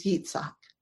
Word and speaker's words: Yitzhak: He Laughs in Hebrew is Yitzhak. That's how you pronounce Isaac in Hebrew.